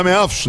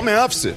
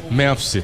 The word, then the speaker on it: Memphis: Bom, não é Memphis, né? A pronúncia correta que o empresário ele me falou aí, os holandeses falam Memphis. – Memphis.